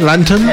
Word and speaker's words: Lanton: And this is called Lantern, which means lantern, but it's spelled L-A-N-T-O-N, Lantern.